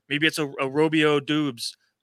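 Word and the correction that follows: Doubs: I think it's pronounced Dubs, like a la sitting on Dubs.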